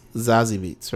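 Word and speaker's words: Zazie Beetz: Is it ZZ or Zaza Beats? – Say what? – How do you pronounce it? Zazie Beats? – Zazie Beats, right? – Zazie.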